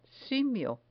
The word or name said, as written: simio